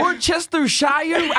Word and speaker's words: Worcestershire: Worcestershire. – Yeah, Worcestershire. – Worcestershire. We've got a lot of American Worcestershire. Is that a place? – Yeah. – Worcestershire.